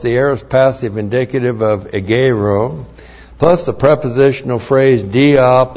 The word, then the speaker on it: egeiro: The conjunction chi is connective, correctly translated and then we have the verb egeiro. E-G-E-I-R, long O. Egeiro.